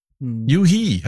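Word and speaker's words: u-he: Stellt euch vor, ich habe heute erfahren, wie man den Namen von der Firma von Urs Heckmann ausspricht auf Englisch. Juhi, habe ich gelernt, heißt Urs Heckmanns Firma. Juhi, spricht man es auf Englisch aus.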